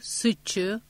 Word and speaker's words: Suzhou: Hangzhou, Suzhou, Wuxi diye okunur.